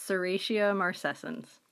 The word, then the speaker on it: Serratia marcescens: As a side note, just wanted to correct Perry's pronunciation of the organism that was in the baby shampoo. It's Serratia marcescens.